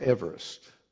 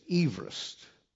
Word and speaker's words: Everest: And something I did not know about this mountain is that I've always mispronounced it. I've always pronounced it Mount Everest. But the actual true pronunciation of it is Everest. It was named after Sir George Everest in 1865.